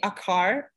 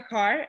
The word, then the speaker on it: Acar: It's G Akar. I'm pronouncing it in American. It's not actually Sezgi, but I'm pronouncing it in American. Sezgi Akar on Instagram, and the same name on YouTube.